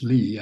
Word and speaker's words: Lie: We pronounce Lee, actually.